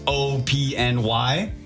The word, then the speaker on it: OPNY: We had a brand new one from, I think this is just pronounced O-P-N-Y.